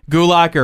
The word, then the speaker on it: Gulak: Cedric Alexander, Lince Dorado, and Sin Cara versus Tony Nese, Drew Gulak, or I think that's how you pronounce the name, right? Gulak? – Gulak, yeah.